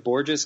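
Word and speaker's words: Bourjos: They signed Peter Borjo? How do you say his name? – I've always pronounced it like Borges because I think people called him Gorgeous Borges for a while.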